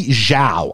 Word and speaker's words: Zhao: Chloe Z-H-A-O, Chloe Zhao, who went on to do Eternals.